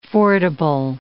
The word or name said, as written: fordable